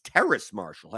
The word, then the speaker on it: Terrace Marshall: Terrence Marshall. Terrace? Oh, it's not even, there's no N in that name. It's Terrace Marshall.